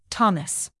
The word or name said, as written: thomas